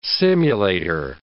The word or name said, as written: simulator